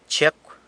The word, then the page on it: Chiecw: sanlucasquiavini